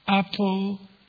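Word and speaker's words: aposiopesis: resulting in what is technically known as apotheosis.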